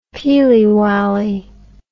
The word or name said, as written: peely wayly